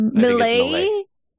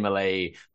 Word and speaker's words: Milei: Am I saying that right? – Milei, I think it's Milei.